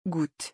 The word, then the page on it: goutte: French